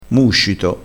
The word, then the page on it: muscito: dialettale